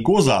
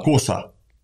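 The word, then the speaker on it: Cosa: То есть наше… На сицилийском, если не ошибаюсь, оно именно не «Коза», а «Коса» произносится.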